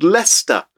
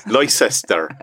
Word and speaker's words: Leicester: And there is a city in the UK called Leicester. And for our American friends, it's spelled Leicester. – Leicester. That's right.